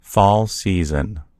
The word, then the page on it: fall season: American English